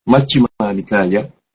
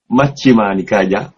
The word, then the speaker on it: Majjhima Nikaya: Chi chữ này nó không có trong tiếng Việt, âm tiếng Việt chúng ta nhiều Majjhima Nikaya, Majjhima ni ngắn, ca giá, ca dài giá ngắn, Majjhima Nikaya. Chúng ta cố đọc đúng là chỉ cái sáu âm thôi, Majjhima Nikaya, quý vị cố cho đúng.